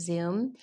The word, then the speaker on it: Zume: It was spelled Zume Pizza, but we pronounced it Zoom.